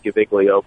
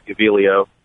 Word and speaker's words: Gaviglio: It's the G is silent. It looks like Gaviglio, but it's Gaviglio.